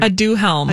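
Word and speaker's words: Aduhelm: A duhelm. – A duhelm.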